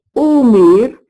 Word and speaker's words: osmer: Le son O, O fermé, qu'on entend par exemple dans le verbe écrit O, S, M, E, R, absent de la mort le roi Arthur, qui se prononce Omer et qui signifie flairer.